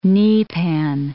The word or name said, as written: kneepan